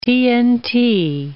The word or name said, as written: TNT